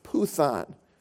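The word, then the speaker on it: puthon (πύθων): Now that word divination comes from a Greek word pronounced puthon. P-U-T-H-O-N is how we would spell it phonetically, puthon. But when you hear that word, think of the word python. Python.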